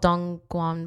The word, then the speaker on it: Dongguan: I think it was north of Shenzhen, in a place called Dongguan, but I might be butchering that name, so I really do apologize.